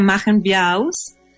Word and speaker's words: Machen wir auch: Claro, sí, el título se pronuncia Machen wir auch.